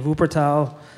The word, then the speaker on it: Wuppertal: At the Music Academy in Wuppertal, Germany.